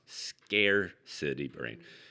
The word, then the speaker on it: Scarcity: For those of you who don't know about the book, it's actually pronounced scarcity brain. But, you know, we tend to renounce things how we want to renounce them. Yes. You know, no judgment. You know, it sounded like New York City around here the last couple of days.